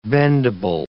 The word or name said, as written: bendable